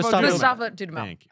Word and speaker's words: Dudamel: Bidamel? – Gustavo Dudamel.